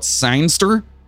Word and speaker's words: zinester: Do you call it Zinester? It's sinister. – Yeah, but it's magazine, so it's a zinester.